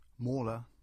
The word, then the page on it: Mawla: English